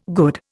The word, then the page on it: good: Indian